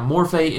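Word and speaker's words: morphe (μορφή): Morphe and schema. Did I say that right? Yeah, morphe and schema.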